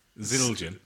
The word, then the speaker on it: Zildjian: Zildjian.